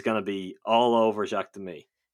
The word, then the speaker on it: Demy: Demy, yeah.